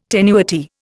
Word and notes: tenuity: Indian

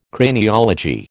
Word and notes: Craniology: American English

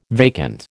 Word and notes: vacant: American English

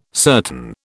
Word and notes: certain: British English

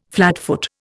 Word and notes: flatfoot: Indian

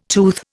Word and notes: tooth: Indian

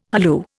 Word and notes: halloo: Indian

